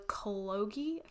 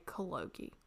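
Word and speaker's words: Kologi: It's K-O-L-O-G-I. So I would say Cologie.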